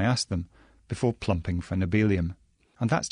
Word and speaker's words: nobelium: To begin with, there's the matter of how to pronounce its current name, nobelium, because it comes from the same root as the Nobel Prize, or nobelium, modelled on the way we say helium.